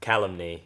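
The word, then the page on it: calumny: au Australian English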